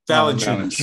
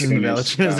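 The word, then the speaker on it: Valančiūnas: Valanciunas. – Valanciunas, there you go.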